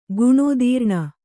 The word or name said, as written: ಗುಣೋದೀರ್ಣ